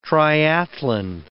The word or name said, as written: triathlon